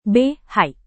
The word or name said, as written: bh